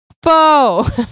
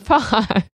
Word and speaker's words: Faux: it's pronounced Faux, not Fox.